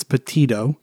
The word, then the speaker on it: Petito: Like literally I have 10 stories myself, but every time I go on Facebook and Instagram, there's something about Gabby Petito. I have a hard time with that word, but that's how it's said. I've heard it. I keep wanting to say Petito with a D, but it's Petito.